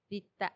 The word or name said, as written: Ditta